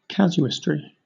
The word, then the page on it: casuistry: British English